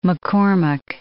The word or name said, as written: McCormack